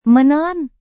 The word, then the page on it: menelan: Indonesian